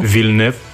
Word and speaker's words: Villeneuve: Villeneuve, more in a French way.